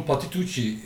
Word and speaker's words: Patitucci: Patatesçi miydi? Patitucci miydi? – John Patitucci herifin adı. İşte biz kendi aramızda Patatesçi olarak telaffuz ediyoruz kendisini.